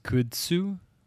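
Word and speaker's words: kudzu: And it's I guess it's pronounced Kutsu. Like, could you sue? – Yeah.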